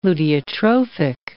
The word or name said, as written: luteotrophic